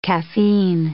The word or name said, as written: caffeine